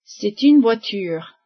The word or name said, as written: voiture